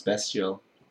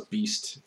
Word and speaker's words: Bestial: And I think it's pronounced Bestial. – Bestial? – I think so. – Best for Bestial? – Yeah. – I thought it was Beast. – No, because it's not spelled Beast. – Oh. – It's spelled Bestial.